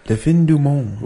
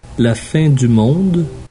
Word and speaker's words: La Fin du Monde: Next beer we're going to do is Le Fin du Monde. – Le Fin du Monde. – Actually, yeah, the D is pronounced Le Fin du Monde. – La Fin du Monde.